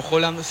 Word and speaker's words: Haaland: Y de esa forma, la Noruega de Haaland, hoy si lo pronuncio como Haaland, sepan entender que es el androide. En realidad así es como se pronuncia en el noruego básico, ¿no?